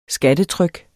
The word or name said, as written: skattetryk